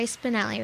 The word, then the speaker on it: Spinelli: Okay, well, this one, his name is Jerry Spinelli, right? – That's right.